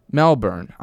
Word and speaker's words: Melbourne: Melbourne.